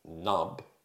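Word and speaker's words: Pnub: After speaking to his equally irresponsible friends, Mike and Nub, with a silent P at the beginning, he discovers that his right hand has a bloodthirsty mind of its own.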